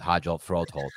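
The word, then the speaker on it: Hjalte Froholdt: Elijah Wilkinson, Hajelt Rudgeholt, and Will Hernandez. – You said that well. I was impressed. – I finally pronounced the name decently with Hajelt Rudgeholt, but I mean, these are undrafted free agents, Wilkinson and Rudgeholt, and then Will Hernandez.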